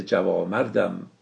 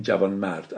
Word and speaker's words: جوانمرد: شرابخانه عالم شده سینه من، هزار رحمت بر سینه جوامردم. در قدیم اینو جوامرد تلفظ میکردن و نونش رو هم نمینوشتن. امروز ما با نون میخونیم و میگیم جوانمرد.